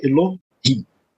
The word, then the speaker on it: Elohim: A palavra Elohim, por exemplo, a primeira né, eh, vogal que aparece, na verdade, é semivogal. Então a sílaba é Elohim. Né, são duas sílabas, só para a gente entender como é né, que isso funciona.